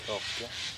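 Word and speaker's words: kråke: Grekel. I don't know how to say crow and I thought I knew how to say it. Grekel. – Kroke. – Oh, kroke.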